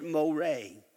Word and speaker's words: Moreh: In your Bible, it's M-O-R-E-H is the way the King James Version. But it's pronounced Moreh, the Valley of Moreh.